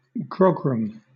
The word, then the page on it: grogram: British English